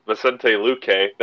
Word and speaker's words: Vicente Luque: And then we get Vicente Luque.